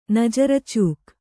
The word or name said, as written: ನಜರಚೂಕ್